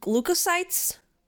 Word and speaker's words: leukocytes: Uh, How do you pronounce that? Lu- leukocytes? – Yes.